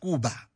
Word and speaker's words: Koba: So it's written Koba, but pronounced Kuba.